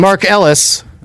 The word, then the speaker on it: Mark Ellis: And Mark Ellis.